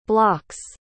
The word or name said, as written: block's